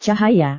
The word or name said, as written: cahaya